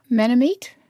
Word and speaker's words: Manameet: Manamit, M-A-N-A-M-E-E-T, which means autumn.